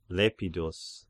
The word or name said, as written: Lepidus